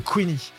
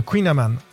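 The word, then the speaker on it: kouign-amann: Parce que les Américains n'arrivent pas à prononcer le nom de notre spécialité bretonne, le Queenie. Le Queen Amman.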